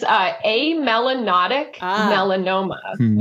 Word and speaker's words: amelanotic melanoma: I'm like, I'm going to just ask how to pronounce what Fred has or had. – I think doctors and the medical profession make things hard to pronounce so that we have job security, right? But it is amelanotic melanoma, which is essentially an oxymoron because it means a non-pigmented pigmented tumor.